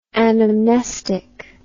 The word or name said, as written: anamnestically